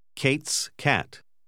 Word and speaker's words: Kate's cat: Faccia attenzione che la K e la C vengono pronunciate allo stesso modo. – Kate's cat.